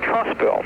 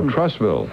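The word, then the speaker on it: Trussville: By the way, the town where I live at, the name is pronounced Trustville. – you know, like a building trust. – Oh, Trustville.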